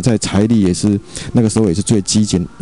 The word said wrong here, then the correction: 吃紧: 最那个这怎么念？ – 最吃紧吗？ – 吃紧，对，叫。